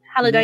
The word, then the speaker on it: Halliday: Basically, the plot follows on from the first book with more secrets of the Oasis founder, James Halliday. Is that how you pronounce his surname? Halliday.